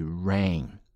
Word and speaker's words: reign: Reigning 这个词呢来自于 reign、啊、当然这个发音呢就跟雨这个音是一样的哈，既可以做名词也可以做动词，指的是统治。